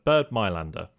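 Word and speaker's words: Bernd Mayländer: There was a quote I can't remember, I'm probably going to mispronounce his name, but Bird Mylander.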